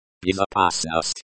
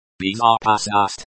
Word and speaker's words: Безопасность: Безопасность. – Безопасность, safety. The stress is on the third syllable.